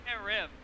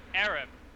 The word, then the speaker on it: harem: She keeps saying harem. As if I know. – Are you racially attacking her? – No, if I were racially attacking her, she would be pronouncing it harem. Pronounce the H.